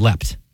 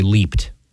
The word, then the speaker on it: leaped: I've always pronounced it as leapt. – When you have the T, but I would pronounce it as leapt, and if it was ED, I would say leaped.